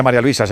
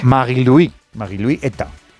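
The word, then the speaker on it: Marie-Louise Eta: Está María Luisa, ¿no? – Claro, bueno, sí. Bueno, no se dice María Luisa, se dice en su... sí. Marie-Louis Eta.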